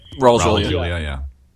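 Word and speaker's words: Raul Julia: Ra's Julia, am I pronouncing his name right? – Ra's Julia.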